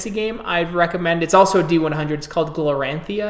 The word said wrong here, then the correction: Glorantha: I'm probably mispronouncing the shit out of it. So if anyone wants to correct me, Glorantha, right?